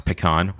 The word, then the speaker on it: pecan: We talked about a little bit of everything from academia and education to local businesses, growing pecan trees, and the right way to pronounce pecan, which is pecan, all you pecan people out there.